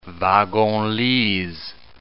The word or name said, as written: wagon-lits